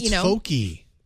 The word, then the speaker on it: Fochi: And so Fochi. – Oh, like maybe focus. It is Fochi.